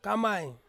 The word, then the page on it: kamai: Ansus